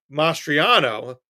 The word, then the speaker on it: Mastriano: Well, you think Democrats made a mistake in the Pennsylvania gubernatorial primaries in boosting Mastriano. Am I saying his name right? – I believe so.